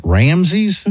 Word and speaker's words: Ramses: Ramses. Am I pronouncing that guy's name correctly? Yeah. That's how you would say that? Ramses? – Mm-hmm. – Like the Egyptian... – Pharaoh. – Yeah, Pharaoh.